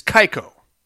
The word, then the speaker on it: Kayako: I doubt any Terror, Kayako. I always fuck up that pronunciation. Correct pronunciation is Kaiko. It's a very quick name. Kaiko. That's how the Japanese do it.